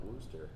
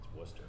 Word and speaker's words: Wooster: Welcome to season 3, episode 5 of Suburban Bourbon, and kind of a cool trip down here to Wooster, Ohio to see Minglewood Distillery. – That's right. And it's not Wooster. Wooster.